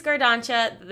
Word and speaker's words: Gardancha: I think it's Gardancha. They messaged us last time because they've submitted before, and they said CH like chocolate, so.